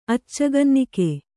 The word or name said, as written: ಅಚ್ಚಗನ್ನಿಕೆ